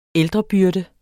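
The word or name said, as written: ældrebyrde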